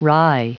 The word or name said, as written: wry